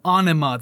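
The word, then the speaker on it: Ænima: And yes, I had to look up how to say that properly, and that's through Adam Jones, the guitarist himself. So you want to debate him, it's Anima. That's what he said, so that's what I'm going by.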